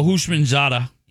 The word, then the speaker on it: Houshmandzadeh: How do you pronounce this NFL star's name? – Who? – TJ Houseman. – Oh, Houshmandzadeh. – There you go. Boom. – Houshmandzadeh.